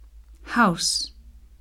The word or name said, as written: house